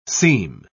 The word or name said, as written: seam